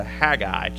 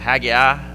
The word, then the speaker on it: Haggai: So if you have your Bibles, turn to Haggai chapter 1. Some people pronounce it Haggai, take your pick on that.